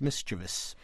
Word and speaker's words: mischievous: And the other big pet peeve is mischievous for mischievous. There's no I at the end of the word. It's V-O-U-S, and the word is pronounced mischievous.